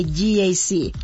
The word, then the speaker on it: GAC: No se dice GAC, se dice GAC, nuevamente GAC, y se maneja mejor de lo que se pronuncia.